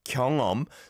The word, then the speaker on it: 경험: 경험, 경험. So I can see it says 경험, but we don't overpronounce the he it there.